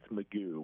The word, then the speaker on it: McGow: So it's spelled McGow, but I've learned it's Magoo, which is Magoo.